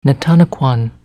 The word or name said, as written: Netannikwan